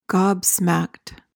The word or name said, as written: gobsmacked